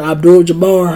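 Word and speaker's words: Abdul-Jabbar: Abdul Jabbar. How you say it? That's right.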